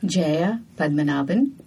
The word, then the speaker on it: Jaya Padmanabhan: Jaya Padmanabhan.